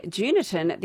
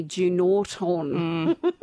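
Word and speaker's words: Junortoun: You can separate the locals from the out-of-towners. Locals say Juniton. The out-of-towners say Junorton.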